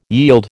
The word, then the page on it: yield: American English